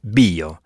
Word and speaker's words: via: Il s'écrit « V-I-A » et se prononce « bio ».